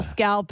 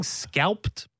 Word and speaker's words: scalloped: If you want to put on the pounds, eat a couple scalloped potatoes. It's just cream. – What are you saying, scalped potato? Scalloped. – You scalp them, buddy. – Scalloped. – Scalloped. – Scalloped. Extra syllable in there.